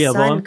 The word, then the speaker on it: Rehoboam: And you see that in the book of 1 Kings chapter 11, and when basically Rehoboam, if I'm saying his name right, his Rehoboam. – Son comes, Rehoboam, thank you, comes in and basically the people ask him, please, make our taxes, lighten our load, lighten our taxes.